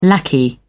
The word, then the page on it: lackey: British English